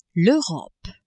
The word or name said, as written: Europe